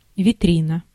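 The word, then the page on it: витрина: IPA: /vʲɪˈtrʲinə/; Russian; vitrina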